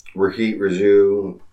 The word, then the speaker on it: Rohit Raju: And then Rohit Raju. Is that how you pronounce his name? – Yes, that's how I pronounce it.